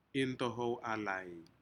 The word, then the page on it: intohou alai: Aneityum